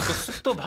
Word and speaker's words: 습도: 근데 이게 비읍이 가끔 어려울 때가 있어요. 받침으로 습도. 있으면. 왜냐면 그쵸? 이게 습 이게 습이 멈춰야 되잖아요.